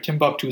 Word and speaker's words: Timbuktu: I think I pronounced it right. Timbuktu, it's spelled Timbuktu.